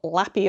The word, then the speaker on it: LLAPY: they've announced this week the inaugural Lappy Awards, which Marina very helpfully tells me that's how it's pronounced, L-L-A-P-Y Awards, and it's just all about Star Trek stuff.